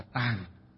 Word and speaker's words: tạng: Thì ở đây chữ tạng ở đây có nghĩa là chữ tạng, còn có một âm nữa đọc là gì, là tàng, đọc là tàng. Chữ tạng còn có một âm nữa đọc là tàng, đều có nghĩa dịch là gì, cái kho, cái nơi chứa đựng.